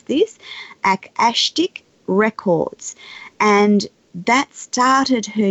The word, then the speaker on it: Akashic: Um, uh, it's Akashic Records. Akashic. And um, yes, it comes from the, uh, a Sanskrit word called Akash, which is um, that from which it means that from which everything is formed.